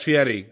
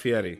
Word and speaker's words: Fieri: Yeah, it's Fieri. I know there's R's in his name, but it's pronounced Fieri.